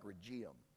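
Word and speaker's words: Rhegium: Just like Regium. That doesn't look like Regium. That's how you pronounce it.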